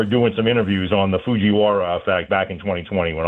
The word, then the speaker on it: Fujiwara: We come back talking to our friend, LSU health climatologist, about the Fujiwara effect.